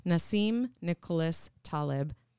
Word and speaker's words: Nassim Nicholas Taleb: Nassim Nicholas Taleb, I hope I said that right.